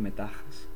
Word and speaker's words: Metaxas: Palos, piedras y pinchos puntiagudos, y le llamaron línea Metaxas. Sí, sí, como Metadona, pero en griego. Y el caso es que los germánicos desconcertados, pues atacaron. – ¿Sabes que probablemente se pronuncie Metajas? – Sí, pues Metajas, me rojas, me...